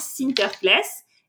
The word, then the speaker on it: Sinterklaas: Aux Pays-Bas, Saint Nicolas, ça se prononce Sinterklaas.